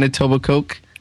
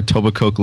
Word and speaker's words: Etobicoke: Do you pronounce the K in Etobicoke? – You do. – That was Etobicoke. – If you pronounce the K, then you are not from this area.